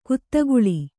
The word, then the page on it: ಕುದಿಗೊಳ್ಳು: kudigoḷḷu